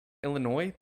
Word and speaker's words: Illinois: Illinois? There's the S is silent? – Illinois. – Illinois. – Yeah. – Yeah, Illinois. – It's silent.